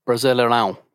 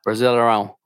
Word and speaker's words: Brasileirão: So first things first, I should say it's Brasileirão. – One more time? – Brasileirão. – Brasileirão. You know what's funny? That ad copy comes with pronunciation keys. And that's not what the pronunciation key says. – Portuguese is a very nasally language, so it's a brasileiro.